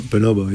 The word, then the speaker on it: Bonobo: Bonobo. – Bonobo, yeah.